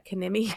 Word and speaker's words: Kinimi: She is Kanimi. I said that right? Yeah, Kanimi Kitchen. I always feel like I get that wrong. KINIMIKITCHEN. So KINIMI Kitchen.